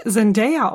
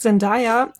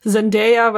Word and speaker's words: Zendaya: Fun Fact, ne, ihr Name wird eigentlich, der wird Zendaya ausgesprochen. Ich dachte früher auch immer, man sagt Zendaya und dann habe ich mal so einen Livestream mit ihr geguckt und dann hat sie jemand gefragt, wie ihr Name ausgesprochen wird und sie dann so, ja, Zendaya, weil so kreativ war meine Mutter nicht, dass ich jetzt Zendaya heiße.